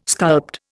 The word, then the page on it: sculpt: Indian